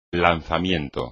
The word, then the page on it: lanzamiento: Spanish